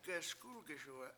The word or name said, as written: geSkUlgiSuwe7